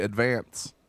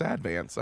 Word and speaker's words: Advance: Well, if you're not from here, a lot of people say Advance. – Advance. – It's Advance. I know this.